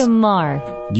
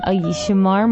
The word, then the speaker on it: Shemar: Shemar, I guess is how you say this. – Shemar. – You say Shemar? – Shemar Moore.